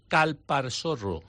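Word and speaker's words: Calparsoro: José Coronado, una película de acción dirigida por Daniel Calparsorro. Lo he dicho bien, que estaba preocupado con el apellido, siempre se me... – ¿Dilo otra vez? – Calparsorro. Vale.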